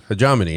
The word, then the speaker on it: Hegemony: Hegemony.